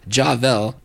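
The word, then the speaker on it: Jah Velle: Ja Vell is spelled J-A-H, one word, V-E-L-L-E, Javel,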